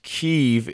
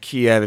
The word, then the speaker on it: Kyiv: I've been told that it is pronounced Kyiv if you are Ukrainian and Kiev if you are Russian. So there are different pronunciations based on the culture.